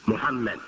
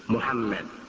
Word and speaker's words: Muhammad: And some spell Ahmed phonetically, A-H-M-A-D. And others spell it phonetically, A-H-M-E-D, Ahmed. And really the mu in Arabic, mu sound, is not, I would say, exactly the U that we have in English. Muhammad. That's the correct pronunciation. Muhammad. So it's a little, it's a blend of the U and the O. It's actually a blend of the U and the O, Muhammad.